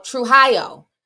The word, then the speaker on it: Trujillo: Oh, Trujillo.